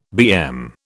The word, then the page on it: BM: British English